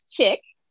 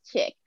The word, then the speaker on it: Chic: Like, Korea, it's C-H-I-C is pronounced chic. And I said, well, in French, maybe it is, but here is chick.